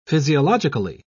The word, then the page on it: physiologically: British English